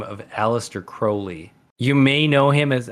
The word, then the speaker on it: Crowley: He rhymed his own name with Holy, which implies that his last name is pronounced Crowley.